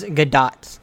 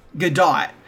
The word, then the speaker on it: Gadot: It's Gadot. – Gadot.